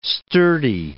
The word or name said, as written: sturdy